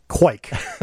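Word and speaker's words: kveik: It's pronounced quike. Quike.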